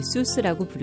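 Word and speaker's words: Seuss: 본래 독일어 발음으로 조이스이지만 많은 사람들이 수스라고 부르자 그대로 굳어진 것입니다.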